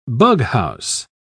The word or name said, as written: bughouse